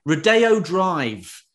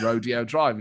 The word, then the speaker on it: Rodeo Drive: And she was like, no, it's Rodeo Drive. I was like, why would they pronounce it like that? The word is rodeo. So it turns out I was wrong. It is Rodeo Drive.